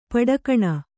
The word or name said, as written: ಪಡಿಗಾಣ್